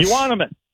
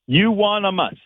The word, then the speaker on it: Euonymus: Is it Euonymus bush? – Euonymus. – Say it again. – Euonymus. – Euonymus. Whew. Okay.